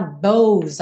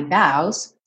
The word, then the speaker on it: bows: Cuidado para não falar bows aí. A escrita sugere uma pronúncia fechada, mas a pronúncia é aberta. I've taken my bows and my curtain calls.